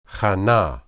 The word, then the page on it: hanah: hebrew